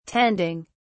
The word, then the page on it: tending: British English